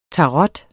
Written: tarot

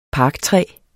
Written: parker

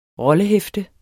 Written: rollehæfte

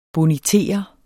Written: bonitere

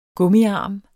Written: gummiarm

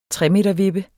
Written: tremetervippe

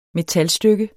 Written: metalstykke

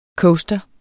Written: coaster

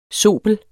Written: zobels

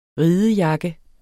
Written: ridejakke

